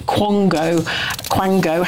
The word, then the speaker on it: quango: People like Kemi Badenoch have said it's total madness from another unaccountable quango. How do you say it? – Quango, of course you do.